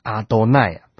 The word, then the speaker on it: 阿多奈: 因为在十诫颁布的时候呢，其中有一诫说不可妄称耶和华的名，所以每当犹太人呢帮以色列人他们在读圣经的时候，只要提到亚伟的部分呢，他们就把它读成阿多奈。